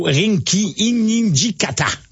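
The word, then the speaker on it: Rinky Hijikata: Aujourd'hui, premier tour, il va jouer contre l'Australien, le Néo-Zélandais plutôt, Rinki Inijikata. – Ouais, c'est bravo ça. Pour la prononciation.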